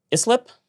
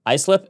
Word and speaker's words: Islip: Islip. Islip airports, dozens of private jets destined for Florida takeoff at such times as 11:42 p.m.